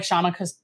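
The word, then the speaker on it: Kshama: And now that Kashama Sawant is doing what she's doing. – Can I tell you, the K is silent. – Shama Sawant?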